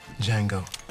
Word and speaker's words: Django: Django. D-J-A-N-G-O. The D is silent.